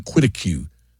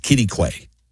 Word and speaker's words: Quitaque: By the way, Kitty Quay is spelled Q-U-I-T-A-Q-U-E. It looks like Quiddicue, but it's pronounced Kitty Quay.